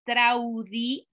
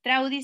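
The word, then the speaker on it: Traudy: Me encuentran con mi nombre, Traudi Ávila. Traudi se escribe T de Tito, R-A-U-D-Y.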